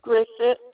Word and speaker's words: Grissett: D-A-R-L-E-N-E. – And your last name? – Grissett. G-R-I. S is in Sam. S is in Sam. E-T-T.